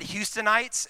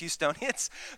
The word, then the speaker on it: Houstonians: One time I said Houstonites, and then somebody pointed out that's not how you say it. It's Houstonians.